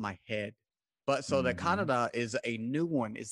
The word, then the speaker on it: Kannada: But for Karnataka, in the books, it says their main language, at least, is pronounced Kannada. – Yes, exactly. Oh, you got it spot Okay. – on.